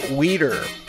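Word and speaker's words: Wieder: That was called All Yari Weeder. Which I know that's pronounced weeder because it's I-E and not E-I. If it was E-I, it would be whiter.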